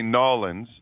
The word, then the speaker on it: Nawlins: Well, if you're from down here, you say Nawlins, like N-A-W-L-I-N-S.